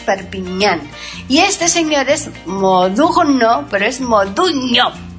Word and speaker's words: Modugno: Es que Modugno es el igual que Perpignan, es ñe, la G. – Sí, la G, N-G. – Se pronuncia ñe.